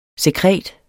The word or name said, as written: sekret